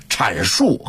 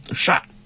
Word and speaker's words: 阐: 这个正确的发音是阐述和阐发，但是北京人啊，老北京人一般都读善，善述善发。